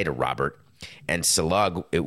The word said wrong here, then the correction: Saleh: would be, is it Salug? Am I really pronouncing his name wrong? I always thought it was Saleh. I'm sorry. It is Saleh.